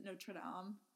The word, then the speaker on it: Notre Dame: You know how, like, the actual, like, French pronunciation is Notre Dame? – Yeah.